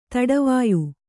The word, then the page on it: ತಡವಾಯ್: taḍavāy